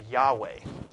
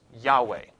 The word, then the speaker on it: Yahweh: which we think is probably most correctly pronounced Yahweh.